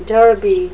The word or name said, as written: derby